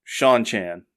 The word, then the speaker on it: Seanchan: And for years, I never pronounced that right. I finally looked it up, and then listening to the audiobook, I had it confirmed. It is pronounced Sean Chan.